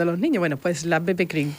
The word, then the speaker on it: BB Cream: Lo he dicho bien, ¿no? – Bebe cream, eso es.